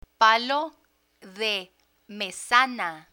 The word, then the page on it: palo de mesana: Spanish